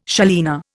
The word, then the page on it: salina: Indian